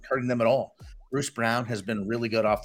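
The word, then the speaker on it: Braun: He's of German descent, I guess, but he pronounces it Brown.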